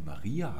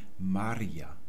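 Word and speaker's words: María: Das Isländische wird immer auf der ersten Silbe des Wortes betont, egal ob es sich um einen Namen wie zum Beispiel Maria handelt. Das betonen wir ja auf dem I, aber im Isländischen sagt man Maria.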